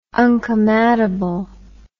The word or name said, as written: uncomeatable